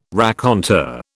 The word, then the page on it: raconteur: British English